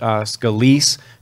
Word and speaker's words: Scalise: I think his name is pronounced Scalise, who was shot, and just terrible, and just a terrible crime.